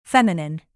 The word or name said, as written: feminine